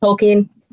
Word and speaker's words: Tolkien: It's pronounced Tolkien, who wrote the Lord of the Ring novels.